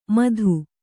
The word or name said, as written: ಮಧು